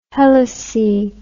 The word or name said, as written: helice